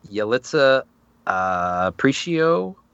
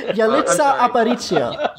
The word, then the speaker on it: Yalitza Aparicio: Leading actress, Yalitza Aparicio Roma. – That's a terrible pronunciation. – Okay, you say it. – Yalitza Aparicio.